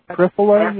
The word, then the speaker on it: Triphala: Could you spell that Triphala, you said? – Yeah, it's Triphala, T-R-I-P-H-A-L-A. T-R-I-P-H-A-L-A.